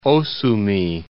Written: Osumi Islands

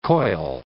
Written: coil

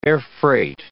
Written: airfreight

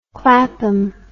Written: clapham

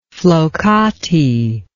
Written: flokati

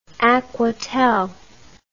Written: aquatel

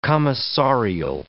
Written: commissarial

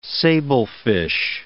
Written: sablefish

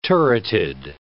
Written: turreted

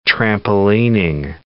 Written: trampolining

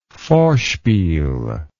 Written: vorspiel

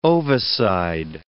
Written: ovicide

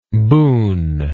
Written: boonless

